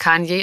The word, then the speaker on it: Kanye: Und ist Kanye ist auch absolut richtig.